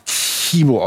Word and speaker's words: Tchibo: Das ist der Tchibo, der wurde damals auch tatsächlich Tchibo ausgesprochen, also, so wie man es ja eigentlich auch schreibt.